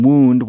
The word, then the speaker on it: wound: Of course, at this time, wound was still pronounced wound, just like the past tense of wind as in wind your watches.